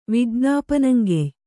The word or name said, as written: ವಿಜ್ಞಾಪನಂಗೆಯ್